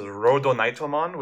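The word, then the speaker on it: Rodonitomon (ロードナイトモン): Yeah, it's a really silly pun, because Lord Nightmon is pronounced as Rodonitomon, which sounds like rhodonite, like the material that their armor is made out of.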